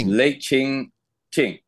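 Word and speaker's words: Lei Cheng Uk: The Lei Cheng Han Tomb. Is that right? – Qing. Lei Cheng Qing. – Yeah.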